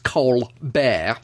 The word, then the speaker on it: Colbert: I don't know if you heard of either of these men, but Alton Brown, the food scientist, and Stephen Colbert, or it's written Colbert, he pronounces it Colbert.